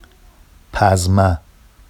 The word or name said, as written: pazma